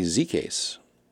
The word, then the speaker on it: Zekas: I was just on the phone with Tony Zekas. Rhymes with suitcase. Doesn't not spelled anything like that.